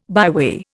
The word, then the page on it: byway: Indian